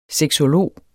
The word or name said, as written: sexolog